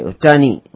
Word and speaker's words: Ohtani: आज मैं ब्लू जेस का एक एक प्लेयर है उटानी के नाम से, उसका OHTANI उटानी उसको प्रोनाउंस करते हैं, मेरे प्रोनाउंसिएशन में गड़बड़।